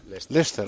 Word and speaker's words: Leicester: ¿Leicester? – Leicester.